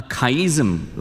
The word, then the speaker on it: chiasm: We call it a chiasm. Like, you can't pronounce it like a chiasm. C-H-I-A, chia, and S-M, a chiasm.